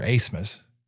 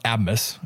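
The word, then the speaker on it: Aßmus: Asmus. Asmus is how you pronounce his name, by the way. That B is not actually a B. It's an S set from German, so it's double S.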